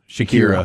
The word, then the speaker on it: Shakira: And Shakira is a, I'd say her name wrong. Shakira? – Shakira.